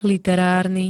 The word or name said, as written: literárny